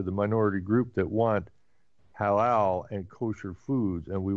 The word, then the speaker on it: Halal: H-A-L-A-H-A-L. – I see how it's spelled, but Halal? – Halal.